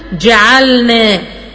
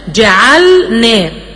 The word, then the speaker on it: جعلنا: لكن لازم أركز جدا، في بعض الناس لما بتيجي تنطق الكلمة القرآنية بتقول جعلن جعلن جعلنا، ما بتنطقش اللام خالص، لا لازم اوضحها واظهرها تماما، جعلنا.